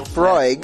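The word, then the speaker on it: Laphroaig: This week, pairing was the Wild Hunter from Leaf by Oscar with the, I can't pronounce that, A-L-A-P-H-R-O-A-I-G. – Go ahead. – Lefroy. How the hell you pronounce that? Laparoge? – Laparoge. Laparoge. That's going on the list. Laparoge. – Petey Scotch. – Can we get with the real? – Lefroig. – Lefroig? Is that how you pronounce it?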